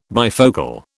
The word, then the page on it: bifocal: British English